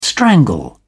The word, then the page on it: strangle: British English